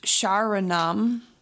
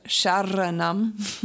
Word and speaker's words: Sharanam: And then the word Sharanam is S-H-A-R-A-N-A-M, and the R, it's not pronounced Sharanam, or Sharanam. To me, what I've heard is it's more of a, it sounds to me like a D sound, Sharanam, Sharanam.